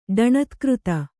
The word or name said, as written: ಡಣತ್ಕøತ